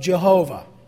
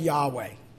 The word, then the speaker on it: Yahweh: His name, that is Obadiah's name, means servant or worshiper of Jehovah, or if you like, Yahweh. Either of those two pronunciations are acceptable for the name of God.